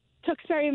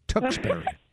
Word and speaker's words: Tewksbury: Tewksbury, Mass. – Tewksbury. Mm-hmm. There was some confusion of the exact pronunciation, and she got it exactly right. Mm-hmm. Tewksbury.